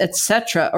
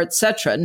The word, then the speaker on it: et cetera: She always pronounces the second T with a D sound and sometimes the first T with an X sound. Just a loving note that it's et cetera or et cetera.